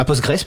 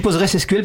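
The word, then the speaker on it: PostgreSQL: Est-ce que dans les formations actuelles des jeunes ingés, est-ce qu'il y a des formations à Postgres SQL, à Postgres plutôt. Alors je dis Postgres SQL parce que ça s'écrit SQL à la fin. Postgres. – On peut dire les deux.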